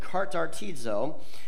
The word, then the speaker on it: katartizo: The word equip is actually a Greek word, and it's pronounced this way, katartizo, and it's a Greek medical term that means to, it's a chiropractic term that means to adjust the bone.